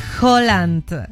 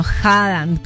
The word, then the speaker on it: Haaland: Bueno, ¿tú sabías que Holland, o bueno, Haaland, como quieras pronunciarlo, rechazó a Tom Holland, o sea, Spider-Man, y lo dejó en visto tras invitarlo a salir?